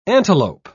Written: antelope

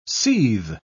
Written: seethe

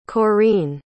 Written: korene

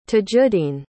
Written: tajuddeen

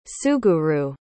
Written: suguru

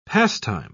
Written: pastime

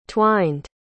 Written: twined

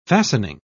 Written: fastening

